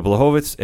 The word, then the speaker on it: Blachowicz: because I apologize. I guess I've been saying it wrong for a couple weeks now. But Blachowicz has been coming in on a fight streak of his own.